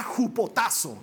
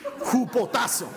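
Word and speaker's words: jupotazo (ὑποτάσσω): Te quiero explicar, este sométanse viene de una palabra griega que se pronuncia jupotazo. A ver, di Jupotazo. Así se pronuncia, no estoy inventando.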